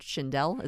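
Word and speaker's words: Shindell: Can you tell me about the headliner, the biggest name on your poster, Richard Schindel? Is that how you say it? Yeah, he's been around 40 or 50 years.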